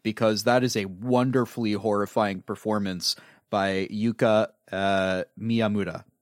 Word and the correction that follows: Yuko Miyamura: Yuko Miyamura.